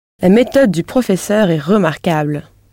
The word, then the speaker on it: remarquable: Right. Remarquable. This is spelt R-E-M-A-R-Q-U-A-B-L-E.